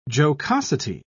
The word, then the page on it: jocosity: British English